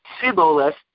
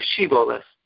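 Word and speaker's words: Shiboleth (שיבולת): The Radak points out because Ephraim lived in a different region and were unable to pronounce the shin sound, so instead they said it as a samach, as a sin, Siboleth instead of Shiboleth.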